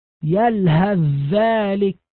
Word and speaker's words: يلهث ذلك: كذلك في قوله تعالى يلهث ذلك، يلهث ذلك، أدغمت الثاء الساكنة في الذال، الثاء تتحول إلى ذال، يصبح النطق بذال مشددة. أيضا في قوله تعالى اركم معنا، الباء الساكنة تدغم في الميم إدغاما تاما، تتحول الباء إلى ميم فينطق بميم مشددة، اركم معنا.